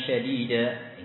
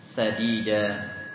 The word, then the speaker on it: sadida: Wa qulu qawlan syadida, ini perkataan yang keras, perkataan yang kasar. Nah, salah. Salah maknanya. Tapi baca dengan huruf sin tanpa titik. Wa qulu qawlan sadida.